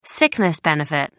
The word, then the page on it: sickness benefit: American English